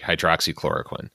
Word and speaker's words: hydroxychloroquine: Did I pronounce that correctly? Hydroxychloroquine. – To the best of my knowledge, yes. Okay. You have pronounced that correctly.